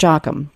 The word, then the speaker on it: Joachim: Dave Joachim, did I say that right? Joachim, Dave Joachim. I'm sorry, Dave.